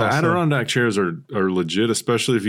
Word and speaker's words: Adirondack: Now, recently I have seen one of our Facebook group members posted a photo of some Adirondack.